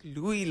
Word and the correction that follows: Lois: I thought it was a silent E. – Yes, I know. But like on a something like that, your Lois Lane.